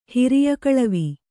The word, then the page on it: ಹಿರಿಯಕಳವಿ: Kannada